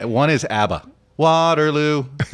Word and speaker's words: Waterloo: But you said it properly, Waterloo. I had this argument with Barack Obama. I said, remember, it's Waterloo, not Waterloo.